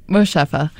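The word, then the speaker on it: Moshfegh: But it's by Otessa Mosingoff? How would you say that? – Mosheffa. – Mosheffa. Otessa Mosheffa. – Okay. You nailed that on the second try.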